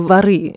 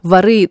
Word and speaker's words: воры: И вот когда э, воры, а не воры, кстати, правильно говорить, воры э, выходили там в 60-м, была большая амнистия в 60-х, и воры начали выходить из лагерей и тюрем.